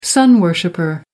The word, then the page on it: sun worshipper: American English